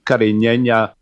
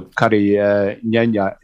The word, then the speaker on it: Carignan: And Carignana, of course, the French variety is called Carignan, which is its origin of the grape variety. So Carignana is just the Spanish pronunciation of the grape.